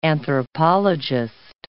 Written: anthropologist

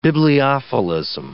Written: bibliophilism

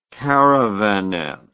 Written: caravanette

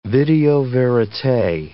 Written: video verite

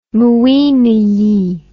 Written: mwinyi

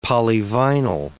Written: polyvinyl

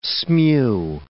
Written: smew

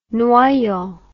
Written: Noyon

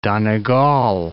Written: Donegal

